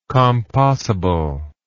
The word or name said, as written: compossible